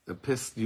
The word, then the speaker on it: epistle: The epistle? Epistle, you pronounce the T? – Epistle. – You pronounce the T? – Yeah.